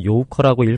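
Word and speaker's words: 요커 (游客): 한자로 놀러 다닐 유, 손님 객자인데요. 중국말로 요커라고 읽고 관광객을 뜻합니다.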